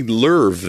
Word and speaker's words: Louvre: Louvre. It's spelled like Favre a little bit. It's got that, you know what I'm saying? – Yeah. – It's how you can imagine Favre became Favre.